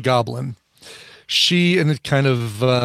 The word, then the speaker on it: Goblynne: Her name is Goblin, and it's spelled Goblynne. Like if her name was Lynn, she just added Gob to the beginning, so she's Goblin.